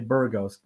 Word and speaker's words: Burgos: So I just say Burgos, which is sounds Burgos. Similar.